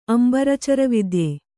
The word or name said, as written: ಅಂಬರಚರವಿದ್ಯೆ